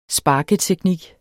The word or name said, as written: sparketeknik